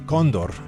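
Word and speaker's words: cóndor: This is the title track off of Hermanos Gutierrez's forthcoming album, Los Ojos del Condor. Condor, actually, stresses on the first syllable.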